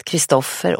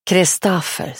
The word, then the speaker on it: Kristoffer: Här i gården har de hetat Kristoffer och Jörgen i generationer. De tyckte det var löjligt av henne att uttala hans namn så som det ska sägas, Kristoffer. Kristaffer, säger de här.